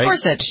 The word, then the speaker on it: Gorsuch: Is that how he pronounces his name? It's Gorsuch, right? – Gorsuch, yeah. Yeah.